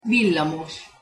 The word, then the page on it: villamos: Hungarian